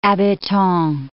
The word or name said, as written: habitan